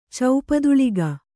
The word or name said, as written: ಚೌಪದುಳಿಗ